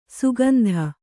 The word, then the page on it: ಸುಗಂಧ: Kannada